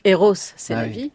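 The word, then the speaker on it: Rrose Sélavy: C'est Marcel Duchamp qui décide de créer un double, donc qui s'appelle Rose c'est la vie, qui s'écrit avec deux R. Qui est une femme, mais en fait qui se prononce Eros c'est la vie, hein, puisque c'est deux R pour le prénom, et qui sous ce pseudonyme va publier des articles, mais va aussi créer des œuvres.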